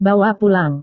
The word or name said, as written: bawa pulang